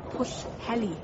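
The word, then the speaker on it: Pwllheli: My schoolmates used to go to Fuheli for their summer holidays. – It's pronounced Pushheli.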